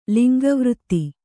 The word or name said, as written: ಲಿಂಗವೃತ್ತಿ